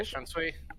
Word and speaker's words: Shunsui: Yeah, Shunsui.